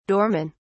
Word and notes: doormen: British English

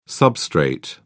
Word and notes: substrate: British English

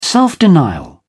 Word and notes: self denial: British English

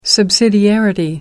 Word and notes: subsidiarity: American English

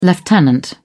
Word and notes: lieutenant: British English

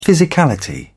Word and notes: physicality: British English

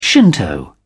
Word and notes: shinto: British English

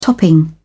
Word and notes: topping: British English

